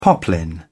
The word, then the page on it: poplin: British English